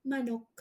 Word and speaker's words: manok: Manok. – Manok, yeah. – Remember? – How you say it? Manok? – M-A. Manok. Here you go. – Manok. Okay, Manok. – Yeah, M-A. Manok. Manok. Is that good? – Okay, yep, that's good.